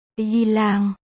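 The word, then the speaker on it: Yilang (伊朗): Iran đọc là Yilang. Phiên âm là Y Lãng.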